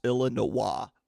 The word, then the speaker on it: Illinois: It is pronounced Illinois, sir.